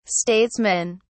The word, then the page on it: statesmen: British English